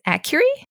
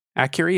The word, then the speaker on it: Akureyri: I believe it's Akureyri. – Akureyri, yeah, that seems right.